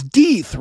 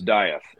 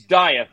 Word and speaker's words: Dieth: now we're up to Death, and it's Death, right? I'm saying it right. – Well, if you're German, it's Death. If you're American, it's Dieth. So yeah. It's Dieth is really the name.